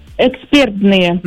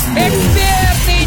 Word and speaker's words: экспертные: Экспертные. – Да! Экспертные, никакие другие! Почему? В данном прилагательном, образованном от слова «эксперт», сохраняется ударение на втором слоге, на чем сходятся абсолютно все словари, поэтому правильно говорить еще раз, как? – Экспертные.